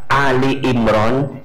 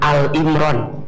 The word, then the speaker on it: Ali Imran: Yang kedua, surat Ali Imran ayat 200. Punten ya, bunda sayang, teteh sayang. Kalau maca Ali Imran, jangan Al-Imran. Bacanya harus Ali Imran. Karena yang dibahas di situ maknanya keluarga Imran.